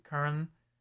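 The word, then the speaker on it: Köln: Yeah, so it's KOLN is how you say Kern, as opposed to, but it's actually Cologne. Like, that's how we say it.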